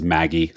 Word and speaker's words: MAGI: Specifically, it's off a measure of income called modified adjusted gross income, or MAGI, which I pronounce as Maggie. Some people say Maggie to each their own, I suppose, but I'm going to call it Maggie.